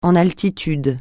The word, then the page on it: en altitude: francais